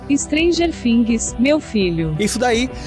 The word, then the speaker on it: Things: Things, Errou! Things.